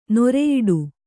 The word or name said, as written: ನಿರಿಯಿಡು